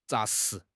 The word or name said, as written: 扎实